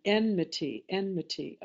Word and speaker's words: enmity: So it is, you do say the N then, enmity, okay.